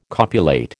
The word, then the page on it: copulate: American English